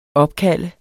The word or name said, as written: OPKALDT